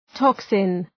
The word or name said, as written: toxin